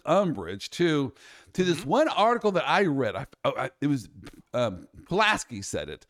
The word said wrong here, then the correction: Psaki: Jen Psaki? – Yes.